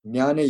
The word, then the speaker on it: Jnana: Then the third one is Jnana Yoga, or it's spelled J-N-A-N-A, J-N-A-N-A in English, is intellectual yoga.